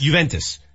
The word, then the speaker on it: Juventus: Juventus.